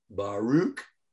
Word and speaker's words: Baruch: B-A-R-U-C-H. Baruch. – All right, Baruch. – Sha'amar. S-H-E. Uh-huh. Apostrophe, if you want. A-M-A-R.